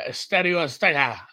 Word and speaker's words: Estadio Azteca: so the, the Bay to Mexico City for uh, Estadio Azteca.